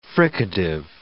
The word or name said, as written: fricative